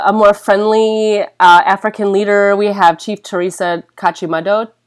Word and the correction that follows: Kachindamoto: Kachindamoto. – Oh, I always say it wrong.